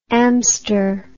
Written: amster

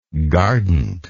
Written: gardant

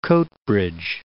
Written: Coatbridge

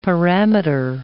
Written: parameter

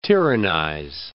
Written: tyrannize

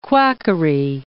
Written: quackery

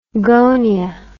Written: gonia

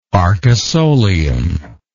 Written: arcosolium

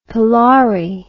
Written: palari

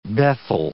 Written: bethel

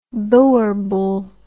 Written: boerbul

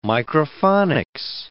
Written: microphonics

